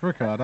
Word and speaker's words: Rookard: No, it's Rickard. R-O-O-K-A-R-D.